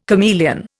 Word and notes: chameleon: Indian

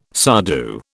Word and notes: sadhu: British English

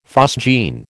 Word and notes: phosgene: American English

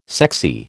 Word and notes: sexy: American English

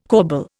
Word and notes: corbel: Indian

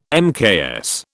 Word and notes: mks: British English

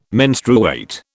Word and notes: menstruate: British English

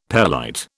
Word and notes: pelite: British English

